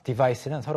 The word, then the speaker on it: devise: 네, 대신에 C일 때는 device, S 발음, 깨끗한 S 발음, 그리고 여기서는 device, Z. With a Z. – 네, Z 발음입니다.